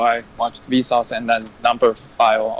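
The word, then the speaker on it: Numberphile: No, file. Number File. That's a Greek for love. It's how you pronounce it.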